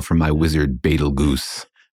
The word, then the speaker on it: Betelgeuse: It looks like you might pronounce it like Betelgeuse or something, but it's pronounced Betelgeuse.